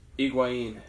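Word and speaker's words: Higuaín: Higuain.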